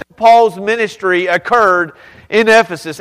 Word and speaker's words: Ephesus: It rhymes with Sisyphus. Ephesus.